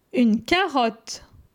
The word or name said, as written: Une carottr